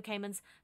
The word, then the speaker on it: Kahneman: And it's like Daniel Kahneman. Is that how you say his name? Daniel Kahneman's Thinking Fast and Slow.